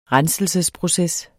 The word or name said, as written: renselsesproces